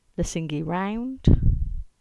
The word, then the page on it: Lesingey Round: English